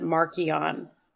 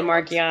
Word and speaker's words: Marchion: if you go to Into the Dark audiobook, they actually pronounce it Markion. So yeah, that's the other one. – I personally say Markeon. Ones. I just like the way it sounds. I'm not a big fan of Marchion.